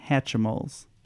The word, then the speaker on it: Hatchimals: We were kind of fighting over what the pronunciation of this is, and we settled on Hatchimals. – That's the exact pronunciation, oh, Hatchimals.